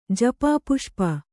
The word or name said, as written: ಜಪಾಪುಷ್ಪ